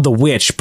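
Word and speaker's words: The VVitch: It's by the guy who did it's called The Witch, but it's spelled like the Vavitch.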